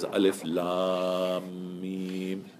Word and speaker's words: alif lam mim: So how do I know to pronounce this as alif lam mim? How do I know? Because if I don't know anything about the Quran and I'm looking at it with like you know the big muddas on it, that doesn't mean I'm going to know how to pronounce it because I might think you pronounce it as pronounce it as alama or something.